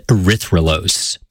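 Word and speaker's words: erythrulose: erythrolose. – I've heard of, like, glucose and fructose, but, like, how do you say that? – Erythrolose.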